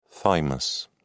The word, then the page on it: thymus: British English